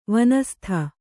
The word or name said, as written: ವನಸ್ಥ